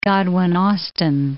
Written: Godwin Austen

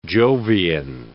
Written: Jovian

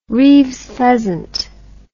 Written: reeves's pheasant

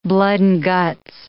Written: blood-and-guts

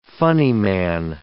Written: funnyman

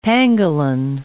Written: pangolin

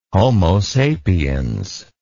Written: homo sapiens

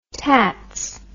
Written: tatts

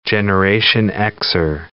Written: Generation Xer

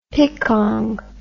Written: picong

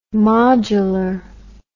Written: modulor

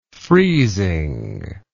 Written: freezing

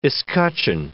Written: escutcheon

